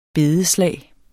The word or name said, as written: bedeslag